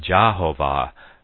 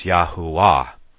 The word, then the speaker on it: Yahuwah: Of course, then we know that the J is not pronounced, and neither is the V pronounced in the Hebrew, so it is not Jehovah, but it is Yahuwah.